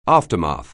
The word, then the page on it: aftermath: anglais